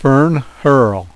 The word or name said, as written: fern hurl